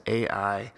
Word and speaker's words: Ai: So Joshua's next action is to send spies to investigate a nearby city called Ai. Ai is correctly pronounced I, but us Americans have seemed to interpret it as Ai.